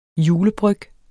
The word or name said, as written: julebryg